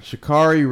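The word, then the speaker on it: Sha'Carri: Shacarri Richardson. Is that how you say her name? – Yeah.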